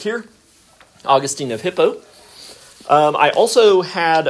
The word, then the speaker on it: Augustine: Now, a quick note, some people call him Augustine and some people Augustine. I prefer Augustine, just like the city in Florida. I feel like that's an acceptable way to pronounce his name.